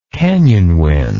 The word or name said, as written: canyon wind